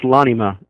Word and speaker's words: L'anima: It's pronounced L'anima. – L'anima. – L'anima in Italian means the soul.